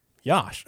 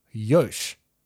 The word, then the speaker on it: Yash: But it is pronounced Yosh. So no, I would say you've got that wrong.